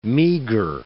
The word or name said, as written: meagre